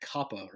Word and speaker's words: COPPA: COPPA or COPA, I've heard both pronunciations,